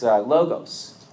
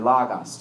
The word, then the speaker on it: logos: It's logos, or logos, depending on your Greek pronunciation.